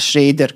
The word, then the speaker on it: Schrader: I uh, ovaj, taj zadatak je dobio Paul Schrader ili pro Schrader, Schrader, kako sam sad čuo da to treba da se izgovara.